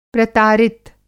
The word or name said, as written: प्रतारित